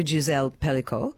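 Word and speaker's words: Pelicot: As well, I'm just wanting to know, you actually dedicated it to the one, well, to Gisele Pellico. Is that how we say her name? – Yep.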